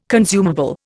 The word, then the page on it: consumable: Indian